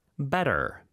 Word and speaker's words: better: Better. B-E-T-T-E-R. – 네, 하지만 발음은 T 발음 안 하고 북미권에서는 Better. 이렇게 부드럽게 T를 발음한다고 했었죠.